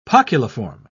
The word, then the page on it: poculiform: British English